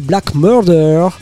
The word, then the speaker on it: Black Merda: C'est écrit Merda. C'est une erreur, faut prononcer Black Murder.